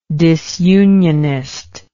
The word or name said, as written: disunionism